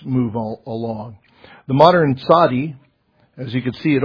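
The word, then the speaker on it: tzadi (צדי): The first syllable is where the emphasis is in this letter, tzadi.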